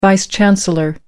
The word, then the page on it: vice chancellor: American English